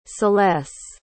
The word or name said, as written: celesse